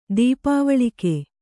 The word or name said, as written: ದೀಪಾವಳಿಕೆ